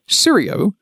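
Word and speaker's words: Sciuro: Serio, with a silent C, is the Latin root word for squirrel, and the name of Cloudflare's solution.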